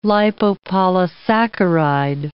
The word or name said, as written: lipopolysaccharide